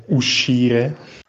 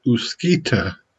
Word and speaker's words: uscire: Maybe you mean um, uscire. Uscire. I thought it was Uskita.